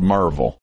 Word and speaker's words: Maryville: And then there's Maryville, Tennessee, but it's called Merville.